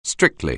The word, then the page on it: strictly: anglais